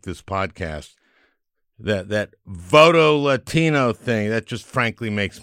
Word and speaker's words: Voto Latino: And that's how you pronounce it, Voto Latino. It's nice. It's not Voto Latino, like some xenophobes might do. Voto Latino. It's not a command.